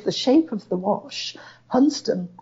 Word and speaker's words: Hunstanton: Yes, it is a wonderful coast with those beautiful high cliffs at Hunston. – Quick side note for people not familiar. Place names are not always pronounced as their spelling would suggest. Hunstanton is by the locals Hunston.